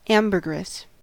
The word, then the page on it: ambergris: American English